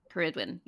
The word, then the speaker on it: Ceridwen: Ceridwen, yeah, that's how you say it, I think. – Oh, yeah, that's actually like, Welsh, yeah.